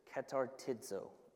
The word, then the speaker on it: katartizo: The word for perfected is katartizo, is how you pronounce it.